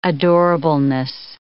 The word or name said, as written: adorableness